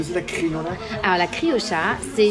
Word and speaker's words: criolla: Alors, il faut, savoir que les Argentins prononcent le che et pas le yeux, donc on dit la criocha.